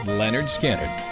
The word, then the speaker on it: Lynyrd Skynyrd: August 13th, 1973, we all learn to yell, Freebird, as Lynyrd Skynyrd releases their first album, pronounced Lynyrd Skynyrd.